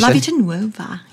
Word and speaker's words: La Vita Nuova: You look at the songs from this album, La Vita Nuova. – Mm-hmm. – Not too bad on the pronunciation. – La Vita Nuova. Yeah, no, pretty good.